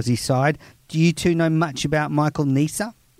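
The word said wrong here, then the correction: Neser: My South African spies tell me it's Nessa. – Good, no, Nessa.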